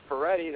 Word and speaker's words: Ferretti: And they can follow me, Al Ferretti. That's Al, A-L. And my last name is spelled F-E-R-R-E-T-T-I.